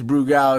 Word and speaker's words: Brugal: Bobby Brugel. – Shouts to Brugel. I mean, it's Brugal. It's Bobby Brugal.